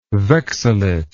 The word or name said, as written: vexillate